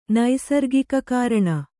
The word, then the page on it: ನೈಸರ್ಗಿಕಕಾರಣ: naisargika kāraṇa